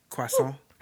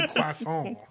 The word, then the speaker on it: croissant: Sorry, croissant. I said it wrong. That's why she had That's why she didn't get it. – Because I didn't say it in French.